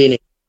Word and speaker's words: Houdini: So number one is Dua Lipa with, I don't know how I'm saying this, Hondini? I'm saying that wrong. – Houdini. – I'm Houdini. – saying that so wrong.